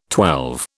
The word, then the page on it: twelve: British English